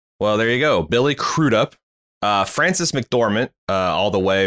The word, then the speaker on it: Crudup: I learned Francis how to pronounce his name correctly because of this movie. Because I watched an interview with him, like a reunion thing on a podcast. And it's apparently Crudup.